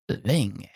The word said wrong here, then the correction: Lang: Would you spell it for me? – G-E-R-H-A-R-D. – So Gerhard. – Gerhard, L-A-N-G.